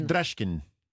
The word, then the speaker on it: drasken: Et le verbe dracher est lui-même emprunté au néerlandais drasken. Je ne sais pas si je le prononce. – Non, on dit drasken.